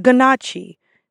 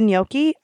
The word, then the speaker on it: gnocchi: I can't tell you how many, out of, let's say, the 100 guests that I serve, there's about 40 that mispronounce this word. They call it the ganache, the gochi, the ganochi, like the ganaki. Like, they mispronounce it so many times. But context clues. I know what they're trying to say. So I always politely say, oh, the gnocchi, absolutely.